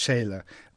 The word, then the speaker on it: Scheele: Yes, his name is spelled Scheele. I think it was pronounced Scheele,